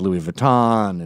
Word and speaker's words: Louis Vuitton: And it's pronounced Louis Vuitton.